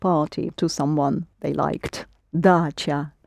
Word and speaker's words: дача: Dacha. – Not quite. – Dasha? – Ч, yes, the дача. – Дача. It's the word дача. And you do spell it in Russian with the letter ч.